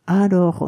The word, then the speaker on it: alors: えー、簡単していることが伝わるのは、あの、アローにびっくりマークなんですけれども、えー、この場合の発音は 1 音節目のアと 2 音節目のローの間で若干の間を取ることが多いです。